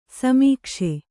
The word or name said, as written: ಸಮೀಕ್ಷೆ